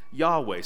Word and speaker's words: Yahweh: So it raises the question then, why in our English Bibles don't they just say Yahweh? Scholars are fairly certain that's the right way to pronounce these consonants.